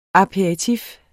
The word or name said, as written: aperitif